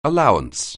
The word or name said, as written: allowance